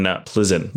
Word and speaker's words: Plzeň: Pilsners originated in Bohemia, or what is now called the Czech Republic, in Plzeň, Bohemia, actually. It's spelled P-L-Z-E-N.